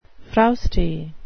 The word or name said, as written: frowsty